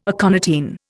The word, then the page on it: aconitine: Indian